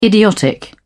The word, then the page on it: idiotic: British English